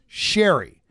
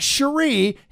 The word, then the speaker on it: Cherie: And I go, it's not Cherie. He goes, no, it's Sherry. He goes, tell Todd she pronounces it the Michigan style, not French. It's spelled Cherie, but she pronounces it Sherry.